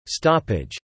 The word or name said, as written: stoppage